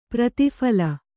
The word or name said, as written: ಪ್ರತಿಫಲ